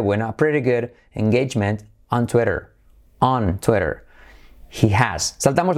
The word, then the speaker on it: Twitter: Pero en inglés, fijaos cómo se dice Twitter. Tenemos la I como E, Twitter, la T como R suave y luego la letra clave R, Twitter. Tú eres, como tú eres rápido, tú eres, listo, tú eres tonto, tú eres. Y ahora tenemos la R, Twitter.